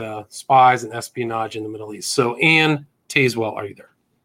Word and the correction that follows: Tazewell: It has a lot of silent E's in it. So it's actually pronounced Anne Tazewell. – Sorry about that. Anne Tazewell. – It's fine, but it's spelled like Tazewell.